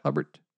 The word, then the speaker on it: Hubert: Hubert.